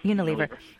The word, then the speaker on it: Unilever: Yeah, Unilever.